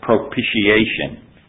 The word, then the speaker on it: propitiation: When I was a little boy, I was taught to pronounce it propitiation.